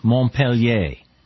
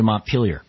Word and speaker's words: Montpelier: I live in Montpellier, Vermont, and that's the French pronunciation. But when they drove the French out of here, they changed the name to Montpelier, which is the anglicized version of it.